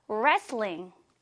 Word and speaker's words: wrestling: 이 발음 체크, 레슬링이 아니고요. wrestling, Korean wrestling 이라고 소개하면 됩니다.